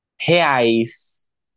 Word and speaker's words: reais: 150,000 reais. I said it wrong. How do you say it? – Hey, ice.